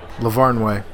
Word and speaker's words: Lavarnway: Lavarnway.